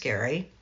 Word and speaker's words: Gerry: G-E-R-R-Y, spelled like Jerry, pronounced Gary.